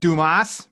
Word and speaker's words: Dumas: Do you say Duma? – You would have said Duma, yes. – Duma. – Well, that's the French pronunciation, yeah. – Okay, because I think I've always said Dumas, because I'm an American. I always say Dumas, huh?